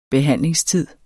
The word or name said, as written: behandlingstid